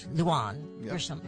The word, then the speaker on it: Lujan Grisham: Lujan Grisham.